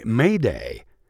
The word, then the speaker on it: m'aidez: In recent years, it's been replaced by May Day, and that comes from the French word spelled M-A-I-D-E-Z, meaning help me. Actually, the French word is pronounced Mayday.